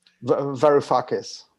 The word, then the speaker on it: Varoufakis: Varoufakis.